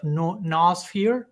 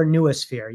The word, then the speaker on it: noosphere: which is the concept of noosphere. That's how you pronounce it? – Yeah, and the noosphere or nuosphere, yes.